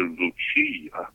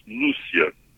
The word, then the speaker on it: Lucia: Sister Lucia, by the way, people always want to make her Italian and call her Sister Lucia, but in Portuguese, her name is pronounced Lucia.